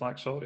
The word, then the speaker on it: Laxalt: Right, my second player is my pronunciation is it Diego Laxalt? Is how you say it? Is it hard salt? Hard X? – Laxalt? I think it's Laxalt, yeah.